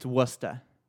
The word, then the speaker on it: Worcester: It's Worcester.